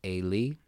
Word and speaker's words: Eli: In Hebrew, it's actually pronounced Eli.